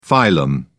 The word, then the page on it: phylum: British English